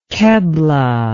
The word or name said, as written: keblah